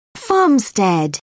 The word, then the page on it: farmstead: British English